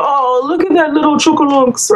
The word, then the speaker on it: Chookooloonks: My blog is Chookooloonks, Chookooloonks, C H O O K O O L O O N K S, Chookooloonks.